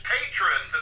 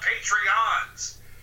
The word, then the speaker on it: patrons: The Paint Podcast and its patrons, it's not Patreons.